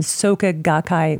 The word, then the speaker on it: Soka Gakkai: And your book is The Star-Spangled Buddhist, Zen, Tibetan, and Soka Gakkai Buddhism, and the Quest for Enlightenment in America.